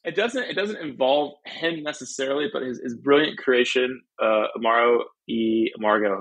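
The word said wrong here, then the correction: Amor y Amargo: Am I saying that correctly? – Southern? Amor e Amargo. Close enough.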